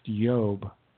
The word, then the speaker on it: Iob: In fact, names like Job, it was really the book of Iob, probably pronounced Yob, because the I's were often pronounced with a Y.